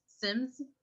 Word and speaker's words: Simz: Sims. – Okay. – S-I-M-Z.